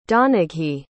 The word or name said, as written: donaghy